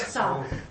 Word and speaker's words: sow: how do you pronounce that? – Sow.